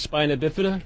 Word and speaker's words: spina bifida: It's pronounced spina bifida.